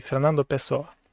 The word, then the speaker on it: Fernando Pessoa: Am căutat uh, oameni uh, spanioli pe YouTube care îi pronunță numele și da, e Fernando Pessoa. Ok, ne-am, uh, ne-am lămurit cu accentul.